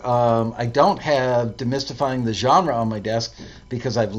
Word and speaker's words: genre: New staff member, and I wanted him to get to know Demystifying the Genre, the French pronunciation, genre.